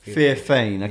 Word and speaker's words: Theophane: Theophane.